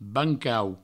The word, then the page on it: bancau: Provençal